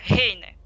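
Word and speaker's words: Heine: По-немецки это произносится Гейне.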